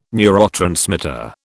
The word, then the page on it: neurotransmitter: British English